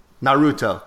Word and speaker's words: Naruto: So I said that my little brother watches Naruto. Did I say that right? – Naruto. – He said it was Naruto, and we got into a full-fledged argument about cartoons.